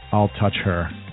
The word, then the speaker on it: Altucher: I am with a new recent friend of mine, James Altucher, and he told me to pronounce it like "I'll touch her." Is that right? – I'll touch her fast.